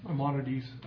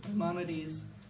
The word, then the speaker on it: Maimonides: Maimonides. – Maimonides, where he was testifying to the fact that the Jews, after Haggai, Zechariah, and Malachi, the Holy Spirit was removed from Israel.